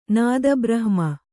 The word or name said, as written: ನಾದಬ್ರಹ್ಮ